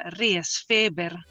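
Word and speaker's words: Resfeber: Si pronuncia Riesfeber.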